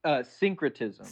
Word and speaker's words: syncretism: How do you say it? Synchronism. – Syncretism. – Synchronism. – Syncretism. So synchronicity. The word synchronicity. – Right. – But with tism.